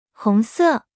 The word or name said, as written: 红色